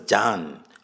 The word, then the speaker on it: Jan: But this one is pronounced as Jan.